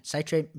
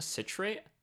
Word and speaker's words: citrate: Citrate meaning the thing you typically associate. Isn't it citrate? – Oh, citrate. My bad. I guess I butchered it the second time around.